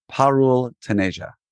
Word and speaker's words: Parul Taneja: Please welcome to the Orthopreneurs Show, Dr. Parul Taneja.